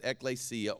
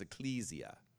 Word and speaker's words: ekklesia: It's the word we call ekklesia. That's how it's technically pronounced, ekklesia or ecclesia. Some people use it like this, ecclesia. I always say ekklesia.